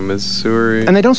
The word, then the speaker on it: Missouri: M-I-S-S-O-U-R-I. – Missouri. – Say it again. – Missouri, okay. There are people who say Missouri. You know that. You've seen this. – Missouri. – They say Missouri for Missouri. And it ends in an I. And I just want to shake them and say, what's going on inside that head of yours where you say Missouri? Why would you say that? There's an I there.